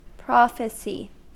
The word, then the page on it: prophecy: American English